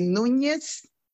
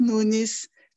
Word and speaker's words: Nunes: So in Spanish, my last name would be Nunes. Mm-hmm. And in Portuguese, it's Nunes, no tilde on top of the N and no Z.